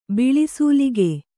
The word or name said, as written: ಬಿಳಿಸೂಲಿಗೆ